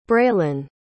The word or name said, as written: braylin